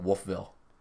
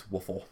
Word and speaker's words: Wolfville: People that don't live there, call it Wolfville. It's Woofle.